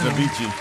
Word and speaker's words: Avicii: Abyssil. How you say her name? A-B-I-C-I-I. Song was big. – Thanks, Avicii.